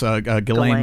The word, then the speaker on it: Ghislaine: this Ghislaine Maxwell.